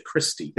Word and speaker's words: Kirsty: K-I-R-S-T-Y? – Yes. – Or is it Kirsty? – It's Kirsty. – Ah, I've been saying it wrong this whole time.